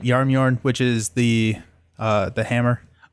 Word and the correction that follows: Jarnbjorn: The reason I couldn't spell it is because it is spelled Jarnbjorn. So Yarnbjorn is probably how it's supposed to be pronounced.